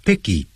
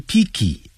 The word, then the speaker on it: picky: choosy， choosy又指的是所谓的很挑剔的。He's choosy about food, F-O-O-D. 他对吃食物很挑剔，他这个也不吃，那个也不吃。所以这样的人呢就很难缠。他们就是小朋友常常讲，爸爸我不要吃这个，我不要吃那个。经常就挑来挑去就 choosy。这个时候也可以用 picky。Pick 本来是拾起来 P-I-C-K 加一个 Y Picky 短的元音 Picky 不要念成 Picky Picky Picky Choosy 啊，这样念通常都要加 About About food 有关食物方面很挑剔。他说 When we run into troubles 当我们遭遇麻烦的时候 They will lend us a helping hand 他们会伸出援手给我们。